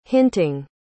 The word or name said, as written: hinting